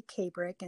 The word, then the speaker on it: Kabrich: Kabrick, and it's my last name is spelled K-A-B-R-I-C-H, and Gail is a short G-A-I-L.